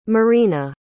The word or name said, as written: maryna